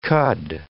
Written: cud

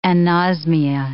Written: anosmia

